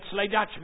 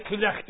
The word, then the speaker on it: Clydach: Everybody knows where Clydach is, and the English people can even pronounce it now, because they used to call it Slydach before, but now they can call it Clydach, you see.